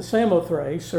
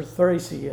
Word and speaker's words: Samothrace: So we ran a straight course to Samothrace, or Thracy as sometimes it's pronounced.